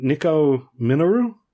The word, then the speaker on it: Nico Minoru: I don't know how to pronounce this name. Nico Minoru? – Oh, yeah. – Mm. Correct.